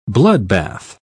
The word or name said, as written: bloodbath